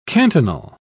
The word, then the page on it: cantonal: British English